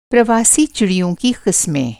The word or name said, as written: प्रवासी